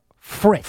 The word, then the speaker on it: Fritz: Well, you certainly don't, because you keep saying his name wrong. It is Jack Fritz. – Fritz, okay. Fritz. I'm sorry. – F-R-I-T-Z.